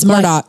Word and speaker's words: Murdaugh: Is it Murdaugh or is it Murdaugh? – It's Murdaugh. – The locals say Murdaugh. With a K.